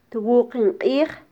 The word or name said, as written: twuqin qij